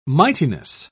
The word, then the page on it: mightiness: British English